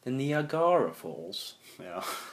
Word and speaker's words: Niagara: Niagara. – Ja, jeg tror det. – Jeg tror det er en uh, Vi sier Niagara, men Niagara.